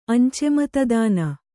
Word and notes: ಅಂಚೆಮತದಾನ: Kannada; añcematadāna

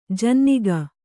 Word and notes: ಜನ್ನಿಗ: Kannada

